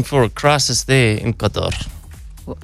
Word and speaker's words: Qatar: I always argue about the pronunciation, commonly referred to as Qatar. I refer to it as Qatar. Qatar, yeah. Um, correct pronunciation, I believe, is Qatar.